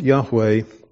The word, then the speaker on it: Yahweh: So this unique name of God that is pronounced Yahweh is explained here in this particular verse as I am who I am.